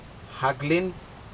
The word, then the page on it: haklin: Aneityum